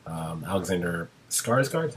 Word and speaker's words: Alexander Skarsgård: Alexander Skarsgård, is how you say his name? – Yeah.